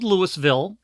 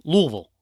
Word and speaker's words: Louisville: Well, because you don't pronounce a lot of the... letters, because it's Louisville is how it's spelled out. Louisville, right. – Kentucky. So, hey, for all of our British fans, it's spelled Louisville, Kentucky, but it's pronounced Louisville. – Yep. – Louisville. And that's actually how it's pronounced.